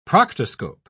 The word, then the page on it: proctoscope: British English